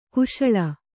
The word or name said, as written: ಕಾಶಳ